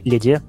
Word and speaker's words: LEDE: И, в общем, ребята, которые были готовы к изменениям, они отделились в собственный форк, назвали его лид, ну или леде, э, леде, как у нас по-разному его называют. – А как правильно вообще произносить? – Лид. – Лид? – Лид.